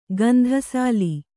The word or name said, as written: ಗಂಧಸಾಲಿ